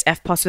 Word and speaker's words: Eftos: I sing and dance as well, but because my last name's Eftos, which is, I tell people when I'm trying to spell it or get them to pronounce it properly, I say it's Eftos without the P.